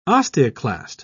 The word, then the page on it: osteoclast: British English